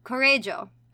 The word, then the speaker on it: Correggio: And they moved once more, this time to um, Correggio, I actually looked up the pronunciations for all of this.